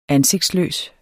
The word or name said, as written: ansigtsløs